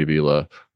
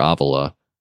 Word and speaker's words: Avila: So yeah, Bobby Avila or Avila, depending on how you want to pronounce it, would come in for me at number five.